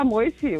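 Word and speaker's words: Moissi: Alexander Moisi, oder so irgendwie. – Ja, Alexander Moisi, kann man Moisi auch aussprechen.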